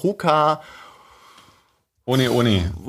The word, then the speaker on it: Hoka One One: Es wird halt hier äh, Hoka One One äh, ausgesprochen, weil äh, die hawaiianische, das ist ein hawaiianisches Sprichwort, äh, Hoka ohne, ohne.